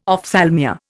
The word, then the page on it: ophthalmia: Indian